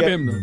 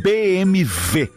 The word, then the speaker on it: BMW: Sabiam que todo mundo fala... pronuncia a marca deste carro errado. – Não é BMW. – É BMV.